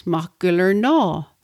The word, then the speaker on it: Machgillernaw: Notice the aw sound at the end, Machgillernaw.